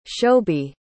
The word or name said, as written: shobi